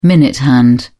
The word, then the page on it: minute hand: British English